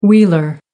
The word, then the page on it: wheeler: American English